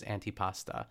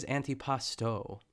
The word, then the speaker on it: antipasto: So we're all learning things today here on The Good Vanilla, that I've been saying it wrong the whole time, but I feel like on menus I've seen it advertised as antipasta. But I guess it is antipasto.